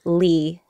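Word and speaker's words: Le: So the traditional pronunciation of the last name L-E is Leigh, but I'm used to calling myself Lee, L-E, yeah.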